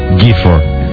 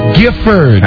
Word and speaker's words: Gifford: Giffer. What? You laugh at me. I tell you already. Kathy Lee Giffer. – Gifford.